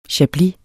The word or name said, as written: chablis